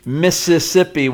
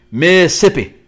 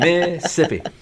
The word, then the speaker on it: Mississippi: Actually, it's not Mississippi with four syllables. It's three syllables. Mississippi. – There you go. – Yep. Mississippi.